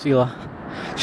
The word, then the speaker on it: Hila: Even though it's spelled with an H, it's Hila.